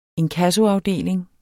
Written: inkassoafdeling

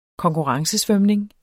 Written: konkurrencesvømning

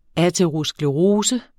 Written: aterosklerose

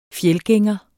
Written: fjeldgænger

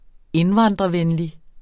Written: indvandrervenlig

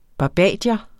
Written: barbadier

